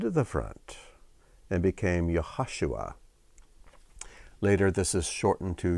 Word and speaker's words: Yehoshua: Now, Joshua is how it's pronounced in English most of the time, but there is no J sound in Hebrew. It would be Yehoshua.